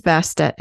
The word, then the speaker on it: Bast: Her name was originally pronounced Ubast or Bast, and some people actually in Egypt today pronounce it like Ba.